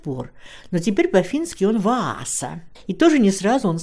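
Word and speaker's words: Vaasa: Сейчас его название произносится по-фински «Ваза», а другой Нейшлотский переулок так и сохранил свое название.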